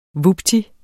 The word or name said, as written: vupti